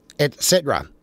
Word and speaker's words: et cetera: So it's et cetera, not ex cetera, which is the way it's been pronounced.